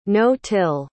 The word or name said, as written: no-till